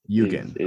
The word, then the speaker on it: Yugen: So we were talking about the album, and so how exactly do you pronounce, what is the correct pronunciation? – Yugen. – Okay. – Yes. That one. I've had Eugen, I've had Eugen, but yes, I've Eugen with the G. It's kind of comes from like a Japanese like aesthetic, basically, or word, or kind of like an ideal.